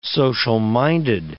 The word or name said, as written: social-minded